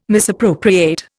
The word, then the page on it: misappropriate: Indian